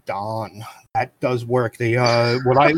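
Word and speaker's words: Daan: His name is Don. So it's D-A-A-N. So it sounds like Don, but it's spelled Dan.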